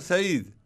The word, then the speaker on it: Said: Ah, Edward Said.